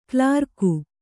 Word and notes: ಕೋಲರಗು: kōlaragu

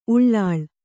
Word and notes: ಉಳ್ಳೊಳ: uḷḷoḷa

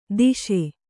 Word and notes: ದೋಷಿ: Kannada

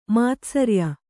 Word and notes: ಮಾತ್ಸರ್ಯ: kannada; mātsarya

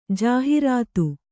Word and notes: ಜಾಹೀರಾತು: Kannada

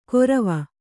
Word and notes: ಗೊರವ: kannada

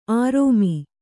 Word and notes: ಆರೋಮಿ: Kannada

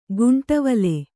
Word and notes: ಗುಂಟವಲೆ: guṇṭavale

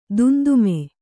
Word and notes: ದುಂದುಮಿ: dundumi

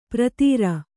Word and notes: ಪ್ರತೀರ: pratīra

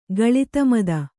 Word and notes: ಗಳಿತಮದ: Kannada

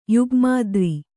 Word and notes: ಯುಗ್ಮಾದ್ರಿ: Kannada

